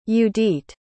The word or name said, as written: judit